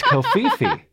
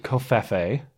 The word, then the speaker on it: covfefe: Now, alternative pronunciations include Kofefe, which rhymes with Pepe, and so people have made the case on that basis. Oh well, this is kind of a Kekistani meme at this point. It should rhyme with Pepe.